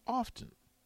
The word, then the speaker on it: often: Do you pronounce the T in often? I it's do a, not. It's a, supposed to be. So how do you say it? Often. Often. It's oftentimes. There's the only T in often when it's oftentimes, but it's often.